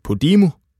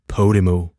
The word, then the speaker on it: Podimo: Podimo udtales ikke Podimo, men Podimo. Og vi bliver ved Podimo, hvis store satsning på podcasting har kastet 3 fastansættelser af sig hos Heartbeats.